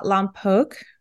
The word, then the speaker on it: Lompoc: So you live in a small city in California called Lompoc.